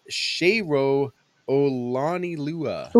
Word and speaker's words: Olanilua: Defensive lineman Joseph Jackson, defensive back Malik Jones, running back Shayro Olanilua. – Ooh. – I said that right, hey? Because I have the phonetic spelling.